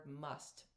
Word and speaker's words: mast: In one of the Indian dialect languages, which I'm not sure which it is, there's the word must, pronounced must but spelled MAST, and that's for the God intoxicated, as to be distinguished from the madness, actual crazy, separate from our infinite self.